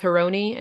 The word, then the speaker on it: Harouni: it sounds more like Harouni than Harouni. It's sort of in between the two pronunciations, but my family has just gone with Harouni and Americanized it.